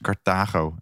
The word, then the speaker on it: Carthago: Ik heb het helemaal verkeerd uitgesproken. Het is Carthago.